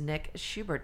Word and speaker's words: Nick Schubert: And I am sitting down today with someone new to my radar, and that is Nick Schubert.